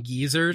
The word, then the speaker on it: Gizur: I always just think it's funny that like it's spelled G-I-Z-U-R. – Yes. – But it sounds like you're saying geezer, just like an old man.